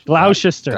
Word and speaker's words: Gloucester: It's Gloucester. – Gloucester. Yeah. Gloucester area.